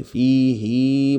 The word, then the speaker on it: فيه: تقرأ كده في قراءة حفص بمد، وده طبعا خلاف قراءة الهاء اللي هي بعد ساكن بعد الياء، يعني فيه في القرآن الكريم بننطقها كده فيه، إلا دي بس، إلا دي بس في القرآن نقولها فيه هكذا بالمد.